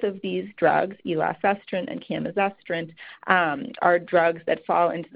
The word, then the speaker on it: camizestrant: So the first one I wanted to talk about is a drug called camizestrant, and that's spelled C-A-M-I-Z-E-S-T-R-A-N-T, camizestrant.